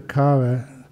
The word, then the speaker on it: kara: Well, usually the kara is that's K uh, long A R A.